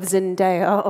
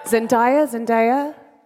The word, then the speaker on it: Zendaya: You may have the fragrant smell of Zendaya on stage. Zendaya? Have I said it wrong? Zendaya. See, I was saying it fucking wrong.